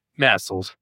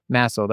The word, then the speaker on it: MASLD: MASLD. – MASLD, okay.